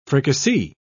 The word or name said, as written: fricassee